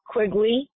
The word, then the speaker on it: Quigley: Colleen, C-O-L-L-E-E-N, Quigley, Q-U-I-G-L-E-Y, my son Riley, my adult son. Riley, R-I-L-E-Y.